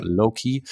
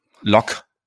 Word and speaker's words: Locke: Ich habe euch die drei Philosophen ja schon ein bisschen angeteasert und wir gucken uns den ersten an, den John Locke oder Loki. – Locke. – Er wird mit E am Ende geschrieben.